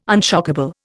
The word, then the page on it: unshockable: Indian